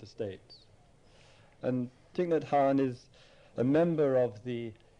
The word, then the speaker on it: Thich Nhat Hanh: Thich Nhat Hanh, which incidentally is spelt T-H-I-C-H, first name, middle name N-H-A-T, and the surname, last name H-A-N-H, Thich Nhat Hanh.